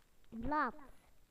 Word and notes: leaf: British English

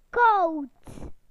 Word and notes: cold: British English

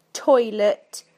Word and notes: toilet: British English

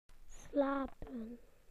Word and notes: to sleep: British English

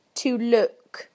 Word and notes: to look: British English

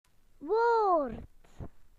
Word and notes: word: British English

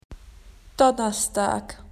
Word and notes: Thursday: Australian English

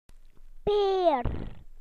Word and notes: pear: British English

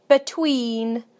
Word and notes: between: British English

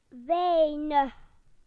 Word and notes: little: British English

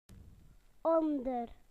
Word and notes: under: British English

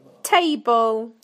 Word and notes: table: British English

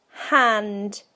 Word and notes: hand: British English

